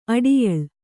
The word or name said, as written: ಅಡಯಾಲ